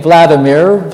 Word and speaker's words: Vladimir: These churches trace themselves back to 988 AD to Prince Vladimir, as Russians pronounce the name, Vladimir.